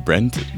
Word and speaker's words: Brent: So my name is Brent Bowers. Brent, B-R-E-N-T, Bowers, B-O-W-E-R-S.